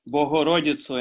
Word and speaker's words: Богородицю: So, if you're Ukrainian and watching this, I hope you notice how I said Богородицю and stuff. Because actually, this is considered traditionalist pronunciation of Church Slavonic, which you can enjoy.